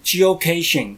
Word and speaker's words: Geocaching: 哦、是啊，它事实上是 Geocaching。